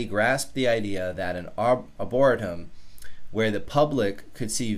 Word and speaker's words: Arboretum: I always get Arboretum, Arboretum, Arboretum. Yeah, that's how it's pronounced.